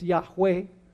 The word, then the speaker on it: Yahweh: We say Jehovah, which is a mispronunciation. I will say Jehovah because that's what we're used to, but probably it's Yahweh or something along that line.